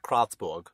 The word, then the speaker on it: Kreuzberg: The next scene takes place at the train station in Kreuzberg, or as we learned how to pronounce it recently, Kreuzberg? – Kreuzberg. – Kreuzberg. – Kreuzberg. – I don't know why you have to, like, get into a lower voice to speak German. – Kreuzberg. – Kreuzberg.